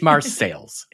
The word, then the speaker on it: Marseilles: And in particular, I want to thank those people out there who have noted that I have been mispronouncing the name of Marseilles. It's not the fancy French name I was using. My apologies. It is Marseilles, okay?